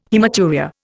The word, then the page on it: haematuria: Indian